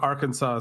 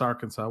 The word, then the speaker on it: Arkansas: Like, I've heard, like, on TikTok or they're on, like, somebody from another country's like, why is Kansas pronounced Kansas, but Arkansas is pronounced Arkansas? Why isn't it Arkansas? – America explain. – Yeah, there you go. Why, American people, why? I'll be the American version of that guy. And the answer is that Arkansas is the French pronunciation because it was a French territory.